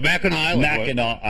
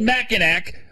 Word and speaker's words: Mackinac: Oh, no, no Mackinac Island. First of all, yeah. – yeah. It's spelled, why do they spell it Mackinac when it's Mackinac?